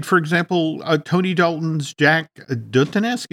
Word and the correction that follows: Duquesne: is that how we pronounce his name? – Jack Duquesne.